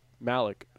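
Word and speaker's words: Malek: Malik.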